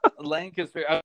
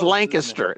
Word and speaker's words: Lancaster: Lancaster. – No, it's pronounced Lancaster. Lancaster.